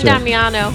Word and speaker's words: San Damiano: You could pronounce San Damiano.